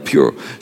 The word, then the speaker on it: pur: It's the word pur, P-U-R, which just means, it's word pronounced pure.